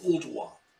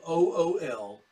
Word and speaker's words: Ooltewah: In our area, I live in a little community called Ooltewah. It's spelled Ooltewah.